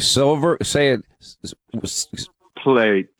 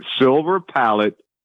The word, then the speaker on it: Silver Palate: How do you say it, by the way? Silver plate. No. – Silver palate pasta sauce.